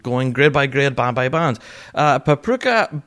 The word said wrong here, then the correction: Papakura: pipe bands, probably butchering that name. Papakura, even. Yep.